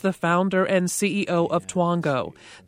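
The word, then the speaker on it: Twongo: Now your mom and Aunt Daisy can just sit back with their smartphones and wait for Twango, Groupon, Living Social, or dozens of other sites to email them the deal or the coupon of the day.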